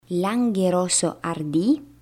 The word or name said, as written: Langhe Rosso Ardi